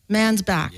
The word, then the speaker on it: Mansbach: is author Adam Mansbach. Did I say that properly? Say it properly for me. – Mansbach. – Yeah. Shit, I should have asked you that before.